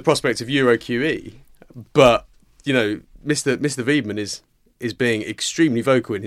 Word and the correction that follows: Weidmann: Weidmann, John. – I will bow to your superior knowledge of German pronunciation there, Stephen.